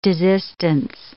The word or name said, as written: desistance